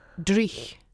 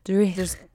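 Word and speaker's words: dreich: someone spelled it as that, which kind of made me sad that I wasn't pronouncing it well enough. But Drich. – Drich. – Yeah. So okay. It's D-R-E-I-C-H.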